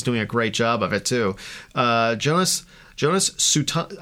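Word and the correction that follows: Suotamo: I don't know how to pronounce his name. Sutomo. – Jonas Sautomo. – Sautomo.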